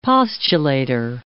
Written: postulator

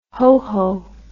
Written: hoho

